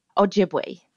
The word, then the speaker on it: Ojibwe: It is Ojibwe or Ojibwe? Ojibwe. Jib. Okay, just yep. like it's spelled.